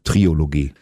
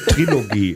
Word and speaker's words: Trilogie: Triologie. Ich bin Klugscheißer, schreibt man mit K und der Trilogie lässt man das O weg.